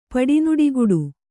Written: ಪಡಿನುಡಿಗುಡು

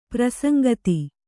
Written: ಪ್ರಸಂಗತ

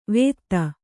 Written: ವೇದ

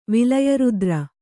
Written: ವಿಲಯರುದ್ರ

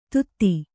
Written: ಧೂತಿ

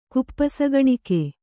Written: ಕುಪ್ಪಸಗಣಿಕೆ

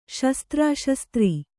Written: ಶಸ್ತ್ರಶಾಸ್ತ್ರ

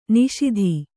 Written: ನಿಷೀಧಿ

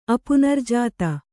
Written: ಅಪುನರ್ಜಾತ

